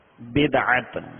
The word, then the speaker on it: بدعت: اس کا درست تلفظ بدعت،